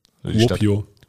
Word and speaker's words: Kuopio: Kuopio. Kuopio. So spricht man das, glaube ich, aus. – Ja, ist gut gemacht.